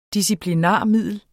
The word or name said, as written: disciplinarmiddel